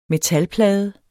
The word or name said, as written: metalplade